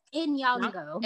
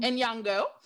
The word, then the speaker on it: Nyong'o: Is it Nyong'o, right? Am I saying it right? – I think it's Nyong'o. – Nyong'o.